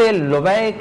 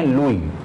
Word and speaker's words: لبے: حضرت لبے کے نام کو عام طور پر لوگ صحیح تلفظ نہیں کر پاتے، لہذا عموماً لوگ کہہ دیتے ہیں لوئی، جبکہ صحیح تلفظ ہے لبے،